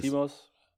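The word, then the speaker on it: Lanthimos: Lantimos für Poor Lantimos, Things. Lantimos, ja.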